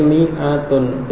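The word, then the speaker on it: mi'atun (مائة): Tulisannya memang ada alifnya ya. Tapi ini membacanya mi'atun. Jadi alifnya tidak dibaca. Pada lafadz mi'atun ada penulisan alif sesudah mim, tapi itu tidak dibaca. Jadi membacanya mi'atun.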